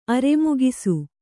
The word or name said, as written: ಅರೆಮುಗಿಸು